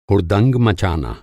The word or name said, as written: हुड़दंग